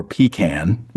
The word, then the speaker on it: pecan: Two big debates are, is it pronounced pecan or pecan? Which one do you go with? – We all say pecan. – Yeah. Okay. See? And then. – It's all acceptable.